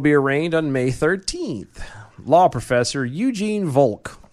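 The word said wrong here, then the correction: Volokh: or Volok, I guess would be the proper pronunciation. – Volok! – Volok.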